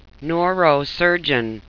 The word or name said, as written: neurosurgeon